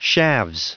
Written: shaves

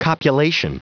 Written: copulation

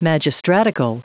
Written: magistratical

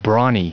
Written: brawny

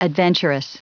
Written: adventurous

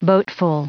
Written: boatful

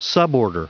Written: suborder

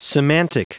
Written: semantic

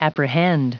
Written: apprehend

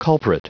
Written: culprit

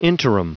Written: interim

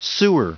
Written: sewer

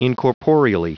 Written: incorporeally

